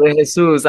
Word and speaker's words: De Jesus: That was beautiful, De Jesus.